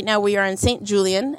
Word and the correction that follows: St. Julian's: Am I saying it right? – St. Julian's. – Yeah, at St. Julian's.